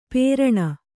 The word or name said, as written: ಪೇರಣ